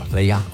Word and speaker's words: playa: I believe it's pronounced play ya. – I did say playa. – Playa. Okay, good. Playa.